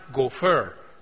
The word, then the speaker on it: gopher: It is a Hebrew word that is pronounced gopher.